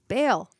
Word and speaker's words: Baal: I'm gonna go to a really weird place, but you pronounced the word B-A-A-L as Baal. I've always said Baal. – Mm-hmm. Me too. Said both. I've said both, and I've heard both.